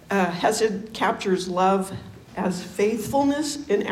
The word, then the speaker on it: chesed: And it's spelled chesed, but it's hesed.